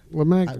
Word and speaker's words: Lamagna: And how do you pronounce your last name? – Lamagna. – Lamagna.